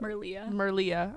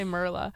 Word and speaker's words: Merlia: Wait, how do you say her name? I can't. – Merlia. – I don't know why I keep wanting to say Merla. Merlia and her two friends are like big into surfing.